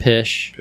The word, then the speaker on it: Pish: How do you say that name? – Pish.